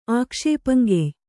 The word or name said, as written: ಆಕ್ಷೇಪಂಗೆಯ್